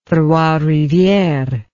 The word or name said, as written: trois rivieres